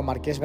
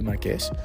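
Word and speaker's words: Marques: Tengo que quizás comparar un poco si gasta o no gasta más batería, porque por ejemplo Marqués Brownlee, lo he dicho bien, ¿no? Sí, Marqués. Estoy ya cansado, estamos domingo a final del día y sigo arrastrando cansancio de la noche sin dormir de Madrid.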